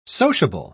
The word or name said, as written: sociable